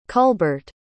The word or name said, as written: Kulbart